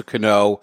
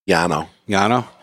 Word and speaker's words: Cano: So the Yankees had a baseball player, Robinson Cano, and it was spelled the same way, so that's why I went Cano. – Yeah. – Really? So the C becomes a Y? – Yeah.